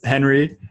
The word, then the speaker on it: Henri: So it's Henry, and then Meijer is, so Henry is spelled H-E-N-R-I, and then Meijer is spelled M-E-I-J-E-R. That's how you spell it. Spelled again, H-E-N-R-I-M-E-I-J-E-R. So it's Henry Meijer.